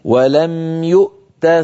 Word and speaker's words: يؤت: فننطق همزة ساكنة ثم تاء شديدة ثم مهموسة ولم يؤت هكذا نقف على هذه الكلمة.